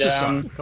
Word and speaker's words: Sasan: My name's Sasan. – Sasan. Okay. – It is Sasan. Go ahead. – Yes, it's not Hassan with an H. – Right, we heard. Mm-hmm. Okay. – S-A-S-A-N.